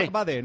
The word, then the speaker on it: Badé: Loic Badé, nos han dicho que se pronuncia. Loic es el nombre y Badé, acentuado en la E.